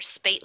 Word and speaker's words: Speight: Every time I go to say his name, I have to remember Speight like eight.